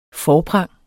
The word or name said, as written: forprang